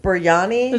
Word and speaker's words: biryani: Biryani.